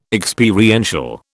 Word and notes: Experiential: British English

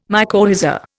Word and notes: mycorrhiza: Indian

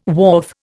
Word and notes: wharf: Indian

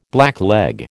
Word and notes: blackleg: American English

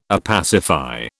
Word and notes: opacify: British English

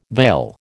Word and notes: vail: American English

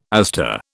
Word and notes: aster: British English

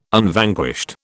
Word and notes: Unvanquished: British English

